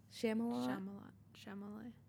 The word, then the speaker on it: Chalamet: Chalamet.